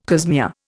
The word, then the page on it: cosmea: Indian